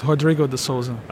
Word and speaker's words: Rodrigo de Sousa: That's pretty much it, Rodrigo de Sousa.